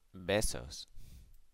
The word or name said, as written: besos